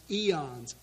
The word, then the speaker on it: aeons: So the way they constructed their heresy, their false doctrine, is they had these things called, I think they're pronounced eons, A-E-O-N-S.